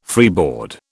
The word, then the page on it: freeboard: British English